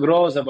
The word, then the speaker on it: Grozev: Christo Grozev, our Bulgarian brother, Shimon's Bulgarian brother, who's been the chief.